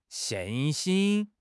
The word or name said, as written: xin4 xin1